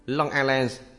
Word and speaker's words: Long Island: Tuy nhiên, âm của chúng ta có thể nghe tách biệt trong Long Island, phát âm không có âm G cứng, và trong Cajon.